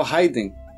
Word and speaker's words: Raiden: E aí eu rebato com o Fábio Araruna, que colocou mais um pro Grandorf por ter incorporado o mestre Hider do Mortal Kombat. – Eu não conheço esse mestre, então eu não sei se eu falei certo. Acertei? – É o Raiden. – Ah, é Raiden?